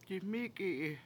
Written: diMIki7i

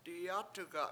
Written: diyAt1ga7

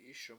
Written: IIS1m

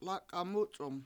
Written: lAk$a7 MUc$1m

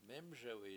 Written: mEmSewi7

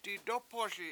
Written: didOpoSi

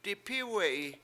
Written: dip7Iwe7i

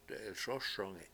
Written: de7ilSOSongi7

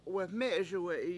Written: weME7eSuwE7i

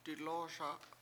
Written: dilOO7oSa

